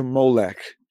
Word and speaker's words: Molech: And so they playfully uh, uh, um, changed the word, the Ammonite word Melech, the Ammonite usage of the word Melech to Molech and they use the same O pronunciation that they use for their word bosheth, which means mm-hmm.